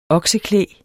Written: okseklæg

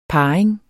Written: parring

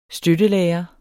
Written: støttelærer

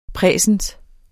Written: præsens

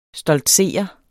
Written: stoltsere

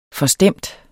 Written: FORSTEMMER

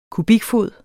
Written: kubikfod

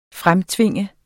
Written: fremtvinge

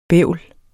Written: bævl

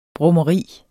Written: brummeri

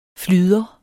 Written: flyder

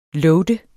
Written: loade

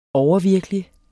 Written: overvirkelig

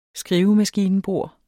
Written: skrivemaskinebord

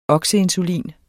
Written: okseinsulin

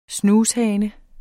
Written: SNUSHANES

